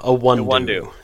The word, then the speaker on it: Iwundu: Did I pronounce that right? Because he's your guy from K-State. – So he is my guy. Wesley Iwundu. Iwundu. Yes.